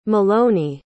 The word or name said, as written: maloney